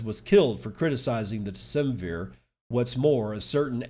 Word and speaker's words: Decemvir: I think it's pronounced Decemvir. D-E-C-E-M-V-I-R.